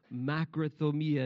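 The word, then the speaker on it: makrothumia (μακροθυμία): Makrothumia is the way that you pronounce it, and I found a wonderful definition of this word back in the language of the New Testament, okay?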